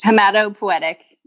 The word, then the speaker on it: hematopoietic: She maintains an interest in all aspects of oncology and a particular interest in the diagnosis and cytology of, okay, if I don't pronounce this correctly, you'll need to correct it for me, hematopoietic tumors? – Hematopoietic. Yep.